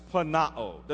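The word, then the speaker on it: planao (πλανάω): That word deceive is a Greek word that we pronounce planao. Doesn't even sound like deception or deceive.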